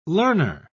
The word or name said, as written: learner